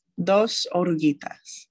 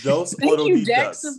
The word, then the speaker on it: Dos Oruguitas: Dos Oruguitas. – Oruguitas. – Dos Oruguitas.